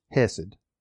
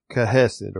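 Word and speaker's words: hesed (חסד): The word mercies there is the Hebrew word C-H-E-S-E-D. I'm going to pronounce it as hesed. The Hebrew people would say kahesed or something similar to that.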